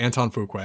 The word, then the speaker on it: Antoine Fuqua: Is it pronounced Antoine Fuqua? It is, isn't it? – I believe so. – Damn yes. – It. Okay. Antoine Fuqua.